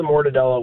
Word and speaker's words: mortadella: So it's 3 stacks of mortadella. Am I pronouncing that right? – Mortadella, that's right.